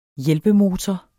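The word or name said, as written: HJÆLPEMOTOR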